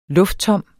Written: lufttom

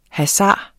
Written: hasard

